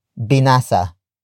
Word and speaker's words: binasa: After that, we have binasa, which is spelled as B I N A S A.